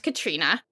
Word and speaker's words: Catriona: the internet was wrong once because they were like, it's Catriona, which is how I pronounce it. It's not at all. It's pronounced Katrina.